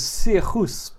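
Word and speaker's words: Sigur Rós: So the band's name is Sigurros, but obviously it's everyone in the West pronounces it Sigurros because that's how it's written.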